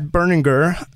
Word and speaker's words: Berninger: And Matt Berninger, it's the hard G.